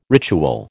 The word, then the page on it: ritual: American English